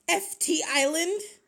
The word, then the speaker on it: FT Island: Fitzland? – What? – Fitzland? – FT Island? – Whatever. Listen, really? I can't think of a way to say it. Hold on, listen. – FT Island.